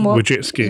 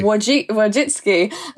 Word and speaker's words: Wojcicki: I can never pronounce her last name. Susan... – Wojcicki. – Wojcicki. I always say Wojcicki.